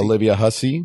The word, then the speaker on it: Olivia Hussey: The Olivia Hussey or Huffy. How do you say it? Hussey? – Olivia Hussey.